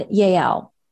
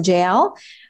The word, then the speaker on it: Yael: We're going to talk about Deborah and Yael. Some, it starts with a J. Some people may pronounce it JL, but that's the chapter we're going to talk about.